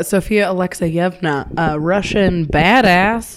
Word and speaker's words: Alekseyevna: Sofia Alekseyevna.